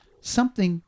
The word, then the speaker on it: something: My first one is when was it okay to pronounce the word something, anything, nothing, everything, with a K on the end? How many people do you know? And we're talking politicians, sportsmen, celebrities, people you just in the street. Something is pronounced something.